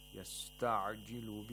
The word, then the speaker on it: يَسْتَعْجِلُ: وَمَا يُدْرِيكَ لَعَلَّ السَّاعَةَ قَرِيبًا قَرِيبًا قَاف has تَفْخِيم and the رَا has تَرْقِيق يَسْتَعْجِلُ بِهَا الَّذِينَ لَا يُؤْمِنُونَ بِهَا يَسْتَعْجِلُ The عَيْن has to be pronounced from the middle of the throat, from the pharynx, and without any قَلْقَلَة. If you say يَسْتَعْجِلُ بِهَا الَّذِي That's incorrect. يَسْتَعْجِلُ بِهَا See, it's completely sucking and there's no movement, vowel movement in it.